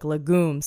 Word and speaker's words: legume: Legume? I said it like legume several times. And then I had to go back and be like, nah, it's legume.